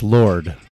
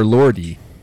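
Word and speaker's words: Lorde: It's Lord. – Oh, is it Lord or Lordy? – Well, it's spelled with an E, but I think okay. It's pronounced Lord.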